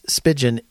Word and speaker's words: Spigen: It's spelled S-P-I-G-E-N. And I say Spigen. I don't know why, because it's kind of like a pigeon.